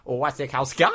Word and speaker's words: Wasikowska: Mia Wasikowska? Is that yeah. I believe the way it is pronounced is Mia Wasikowska.